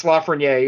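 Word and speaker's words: Lafrenière: Lafreniere is how I would pronounce it. – It's Lafreniere. It's French. He was a former number one overall pick.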